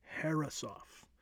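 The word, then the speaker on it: Herreshoff: Harrisoff, that's right on the pronunciation, Harrisoff.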